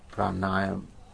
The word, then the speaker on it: pranayama: A number of Sufi orders actually incorporated some of the Hindu practices, such as pranayama.